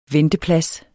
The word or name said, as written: venteplads